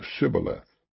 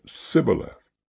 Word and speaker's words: Shibboleth: And said they unto him, Say now Shibboleth. And he said, Sibboleth, for he could not frame to pronounce it right.